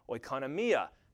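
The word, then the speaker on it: Oikonomia (οἰκονομία): And it's the Greek word oikonomia. You can hear it in that. It sounds like the word economy, doesn't it?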